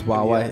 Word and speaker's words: Huawei: Yeah, well, it's spelled like HUA. – Yeah, yeah, but it's Huawei.